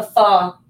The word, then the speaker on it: pho: and then you could make it almost like a pho. Is that how you say it? – Yeah. – By yourself,